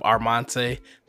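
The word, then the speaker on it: Armont'e: Look up Armonte. That's spelled A-R-M-N. Dang, I can't even spell my name. A-R-M-O-N-T apostrophe E.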